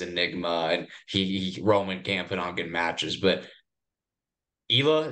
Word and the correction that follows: Ilya: is that how you say it, right? – I think it's Ilya. – Ilya, yeah. Ilya.